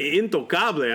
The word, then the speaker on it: Intocable: He is incontable. Did I say that right? – Intocable. – Intocable.